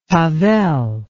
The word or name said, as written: tavel